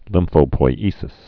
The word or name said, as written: lymphopoiesis